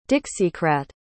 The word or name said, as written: dixiecrat